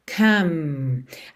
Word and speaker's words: come: Have come. Atenção aí a essas palavras que terminam em E, mas o E não é pronunciado. Você esquece dele completamente. Have termina no V. Have. Come termina no M.